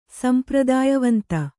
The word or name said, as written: ಸಂಪ್ರದಾಯವಂತ